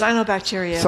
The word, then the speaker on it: cyanobacteria: Do you say cyanobacteria? Is that how you say it? – Cyanobacteria. – Cyanobacteria. Yeah.